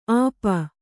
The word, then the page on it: ಆಪ: āpa